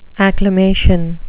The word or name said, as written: acclamation